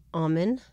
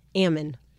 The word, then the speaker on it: almond: There's two different ways, apparently. There's almond and there's ammon.